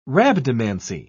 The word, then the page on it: rhabdomancy: British English